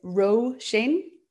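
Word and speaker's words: Róisín: So if you're curious, my name is Roisin. So it's R-O Fada, and that makes it a broad vowel, Ro. And then S-I Fada. So I Fada makes it a broad vowel again because it's got the line above, which instead of ah means e, so Roisin.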